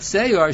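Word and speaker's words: se'or (שיאור): But se'or, shin yud aleph vav resh, it's spelled with a yud in the Mishnah, that's a different din.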